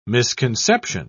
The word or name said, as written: misconception